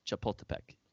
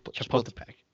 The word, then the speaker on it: Chapultepec: Chapultepec. – No, it's Club de Golf Chapultepec. I looked it up. – Chapultepec.